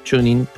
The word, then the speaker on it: TuneIn: Se escribe T-U-N-E-I-N, Tunein, ¿no? Tuning, digamos con una pronunciación.